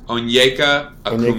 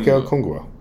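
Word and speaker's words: Onyeka Okongwu: Onyeka Okungwu. – Onyeka Okungwu.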